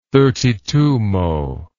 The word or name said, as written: thirty-twomo